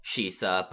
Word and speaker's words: Shisa: It's a weird thing, because in the ancient Ryukyuan like language, like, it's spelt Shisa, but it's also like the way that it's been said and like phonetically spelt throughout it's turned out to be a lot more similar to, as you said, like Roman Caesar.